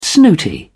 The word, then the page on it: snooty: English; British English